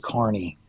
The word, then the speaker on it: Kearney: Like just like random places like Kearney, Nebraska, which is spelled like Kearney, but it's like they say Kearney.